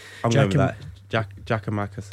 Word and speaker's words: Giakoumakis: I mean, it was butchered many times on this show, and Atlanta have issued a pronunciation guide for anyone, because he was still called, what was the common one that you always hear? Giamakis.